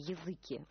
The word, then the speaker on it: языки: Ну, надо сказать, что у Ломоносова, еще во времена Ломоносова, слово «языки» в значении «народ» имело другое ударение – «языки», да? – Ну, и вспомним, Пушкинское вот.